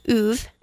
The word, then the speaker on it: Ove: But it's called A Man Called Uwe, and that is how you pronounce it. I looked it up.